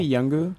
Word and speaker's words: Yungoos: Did you just say Yungu? – The Yungus.